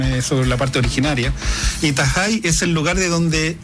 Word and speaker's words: Tahai: Convengamos que se escribe T-A-H-A-I, porque muchos preguntaban cómo se escribía para buscar. – Sí, que en la isla la H se pronuncia como una J más suave. Tajai.